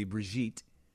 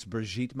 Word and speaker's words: Brigitte: The French pronunciation would be Brigitte. So I'm going to say it's Brigitte Macron.